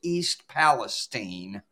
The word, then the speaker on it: East Palestine: And by the way, it's pronounced by the locals East Palestine.